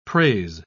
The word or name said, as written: prase